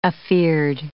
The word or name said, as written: afeared